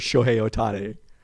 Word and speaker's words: Shohei Ohtani: We had our hopes so high for Shohei Ohtani, which apparently is how it's pronounced.